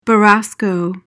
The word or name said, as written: borasco